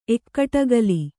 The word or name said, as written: ಎಕ್ಕಟಗಲಿ